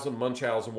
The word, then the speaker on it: Munchausen: Some people will pronounce it different ways, but it's Munchausen, whatever you want to call it.